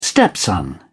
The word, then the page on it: stepson: British English